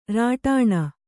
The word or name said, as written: ರಟನ